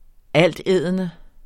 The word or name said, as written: altædende